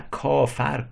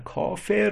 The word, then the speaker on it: کافر: نکته دیگه این تناسبیه که حافظ خیلی جا داره، طریقت تر در طریقت و کافر. کافر در عربی کافر اسم فاعله، ولی ما در فارسی خیلی جا کافر تلفظ کردیم و با چاکر، چاکرم امروز میگیم چاکر، چاکره، با اون قافیه کردیم.